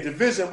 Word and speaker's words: DVSN: Yeah, Division, but it's spelled D-V-S-N.